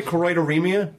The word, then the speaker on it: choroideremia: Do I pronounce it right? Choroideremia? – Perfect.